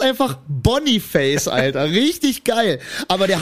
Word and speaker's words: Boniface: Der heißt einfach, no joke, und es wird auch so ausgesprochen, der heißt einfach Boniface.